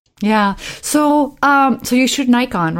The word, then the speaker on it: Nikon: I have done a lot of work for Nikon, which is the correct pronunciation, which is, when I get pretentious, that's how I say it.